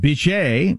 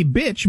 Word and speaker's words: Bitche: I was going to pronounce it Bitche because it's B-I-T-C-H-E, but the E is silent. It's actually pronounced Bitche, I believe.